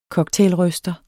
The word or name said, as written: cocktailryster